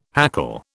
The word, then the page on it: hackle: British English